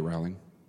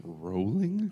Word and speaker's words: Rowling: Rowling. – I don't think you're saying that right. – Rowling? – I believe it is. – Rowling sounds cooler. – Someone told me it rhymes with bowling.